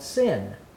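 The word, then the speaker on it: sin (שׂין): And if it doesn't have the dot on that side and it's pronounced as s, it's called sin.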